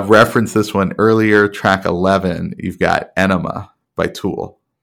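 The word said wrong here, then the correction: Ænima: Yeah, so I think it's actually pronounced Anima, oh. because it has that weird little... – Yeah, like, I did. Yeah, thing. That one was Anima. Yeah, okay.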